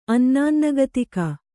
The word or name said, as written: ಅನ್ನಾನ್ನಗತಿಕ